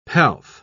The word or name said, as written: pelf